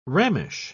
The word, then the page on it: rammish: British English